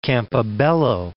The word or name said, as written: Campobello